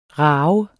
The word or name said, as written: ravende